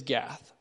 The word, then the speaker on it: Gat: So the first city he mentions is Gath. It says, "Tell it not in Gath." Now, the Hebrew word for Gath would be Gat, and the word for tell is Nagad.